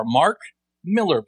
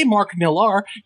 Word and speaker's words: Mark Millar: It is Miller, M-I-L-L-A-R, Mark Miller, pronounced exactly the same as Frank Miller. It's not a name like Sienkiewicz or Nicieza that's really different. It's Mark Miller, and if you say Mark Millar, you sound like a pirate with a head wound.